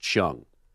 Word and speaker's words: Qiang: Now, it's spelled, Chung is spelled Q-I-A-N-G, but pronounced Chung. So her name is Wang Chung.